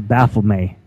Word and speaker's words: Baphomet: That is that's a Baphomet. It's some May is M-E-T is pronounced May, Baphomet.